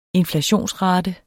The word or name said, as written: inflationsrate